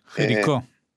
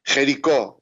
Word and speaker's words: Jericó: ¿cómo se dice Jericó? – Jericó. – Jericó, apenas nosotros entramos a Israel.